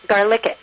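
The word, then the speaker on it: GarLICit!: Yeah, it's pronounced Garlic It.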